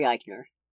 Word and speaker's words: Eichner: The main lead of the movie, Bobby Lieber, played by Billy Eichner? Eckner? I'm probably butchering his last name. – There's an I in it, right? – Yeah, there is an I in it. – Probably Eichner.